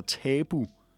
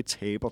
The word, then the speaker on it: tabu: Og det er altså tabu torsdag, og ikke taber torsdag.